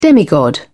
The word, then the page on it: demigod: British English